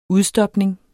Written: udstopning

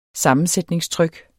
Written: sammensætningstryk

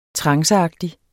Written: tranceagtig

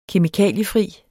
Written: kemikaliefri

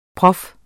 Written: prof

